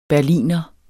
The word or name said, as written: Berliner